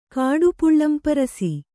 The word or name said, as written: ಕಾಡುಪುಳ್ಳಂಪರಸಿ